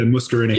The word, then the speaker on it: muscarinic: Okay, good, good but like there's the mucineric, I think is how you pronounce it. Yeah, muscarinic. Yeah, yeah.